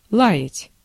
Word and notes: лаять: ˈlajɪtʲ

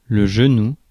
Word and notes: genou: /ʒə.nu/

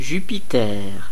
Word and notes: Jupiter: France; [ʒy.pi.tɛʁ]

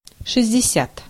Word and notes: soixante: /swa.sɑ̃t/; France

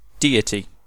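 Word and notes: Tanrı: Turkish

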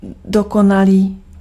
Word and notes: parfait: France; [paʁ.fɛ]; French

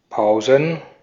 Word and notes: Pausen: /ˈpaʊ̯zən/; [paʊ̯zn̩]

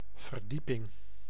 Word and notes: verdieping: [vǝɾ.diː.pɪŋ]; /vǝɾ.di:.pɪŋ/

